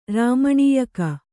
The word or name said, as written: ರಾಮಣೀಯಕ